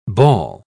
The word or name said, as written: ball